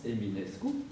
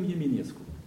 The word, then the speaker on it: Eminescu: C'est que, vous voyez, le nom de ce célèbre écrivain roumain, en Roumain de Roumanie, disons de Bucarest, se prononce Eminescu, alors qu'en Roumain de Moldavie se prononce quelque chose comme Yeminescu.